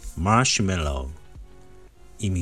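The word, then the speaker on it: marshmallow: これはですね、マッシュメロー。意味はマシュマロですね。ちょっとね、発音が、えー、違いますよね。マッシュメローと発音します。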